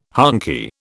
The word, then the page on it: honky: British English